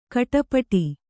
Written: ಗೌಡಪಟ್ಟು

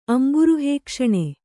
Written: ಅಂಬುರುಹೇಕ್ಷಣೆ

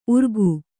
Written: ಉರ್ಗು

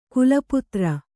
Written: ಖಲಪಾತ್ರ

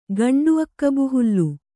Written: ಗಂಡುಅಕ್ಕಬುಹುಲ್ಲು

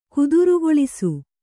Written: ಕುದುರುಗೊಳಿಸು

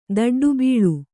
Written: ದಡ್ಡುಬೀಳು

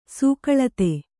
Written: ಸೂಕಳತೆ

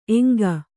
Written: ಏಂಕು

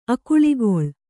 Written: ಅಕುಳಿಗೊಳ್

